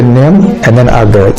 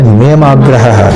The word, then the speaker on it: niyamagraha: Don't split it, don't say niyam and then agra, say niyam agraha. It is one continuous word as written in the scriptures.